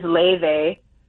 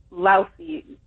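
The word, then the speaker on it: Laufey: Now, explain how it's spelled, because the way it's said is different than how it's spelled. – Correct. So her name is spelled Lausey. So you may think, oh, Lausey. No.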